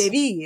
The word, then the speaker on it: Devi: And so the E is pronounced as an A. – Oh, that makes a lot of sense. – Devi is actually how it's pronounced.